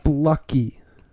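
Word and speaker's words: Fluckey: Yes, I said that correctly. Blucky.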